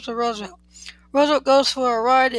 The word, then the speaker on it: Roosevelt: His last name is, according to Roosevelt himself, pronounced as if it was spelled Roosevelt. That is, in three syllables, the first syllable as if it was rose.